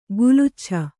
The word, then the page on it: ಕೆಲಚಾಚು: kelacācu